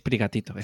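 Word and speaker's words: Sprigatito: Spigatito. Es prigatito, es prigatito.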